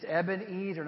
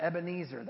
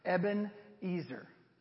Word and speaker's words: Ebenezer: Say Ebenezer. It's Ebenezer, not Ebenezer. That's the guy who I just showed you a picture of. Ebenezer is how you say it.